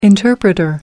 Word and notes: interpreter: American English

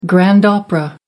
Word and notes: grand opera: American English

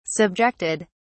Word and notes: subjected: British English

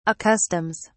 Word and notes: accustoms: British English

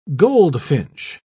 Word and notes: goldfinch: British English

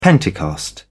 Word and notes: Pentecost: British English

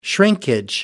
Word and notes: shrinkage: British English

